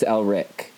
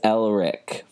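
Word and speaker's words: Ehrlich: Elrich. – No, it's Elrich, the C-H. – Under 5 minutes and we're already having struggles with pronunciation. – Max Elrich, for sure.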